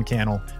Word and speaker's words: Cannell: My name is Sean Cannell, rhymes with YouTube channel, and I look forward to seeing you in the next episode.